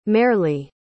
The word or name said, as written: marely